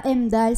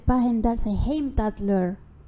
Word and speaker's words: Heimdallr: C'est pas Heimdall, c'est Heimdallr.